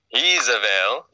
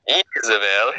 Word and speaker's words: Jezebel: And so a lot of times there's Jezebel. You have to pronounce it like that. – Jezebel, his wife.